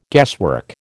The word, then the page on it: guesswork: American English